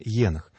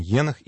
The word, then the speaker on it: Енох: Кстати, надо сказать, что есть два представления о том, как правильно произносится имя этого праотца, о котором мы сейчас говорим, Енох или Енох. И, насколько мне известно, существует и то, и другое произношение существует ровно в одинаковых, на одинаковых основаниях, поэтому какому-то из них давать предпочтение сложно.